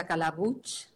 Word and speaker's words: Calabuig: Es muy fácil de pronunciar, es Calabuig, como si tuviera una che al final.